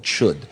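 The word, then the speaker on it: Chöd: And there's a whole tradition in Tibetan Buddhism called Chud. C-H-O with an umlaut D is how we spell it in English, how it's pronounced,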